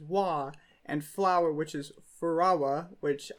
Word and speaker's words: Furawa: F- Ferrara? – For for Furawa. It's F U R A W A. I think it's Furawa. – Furawa.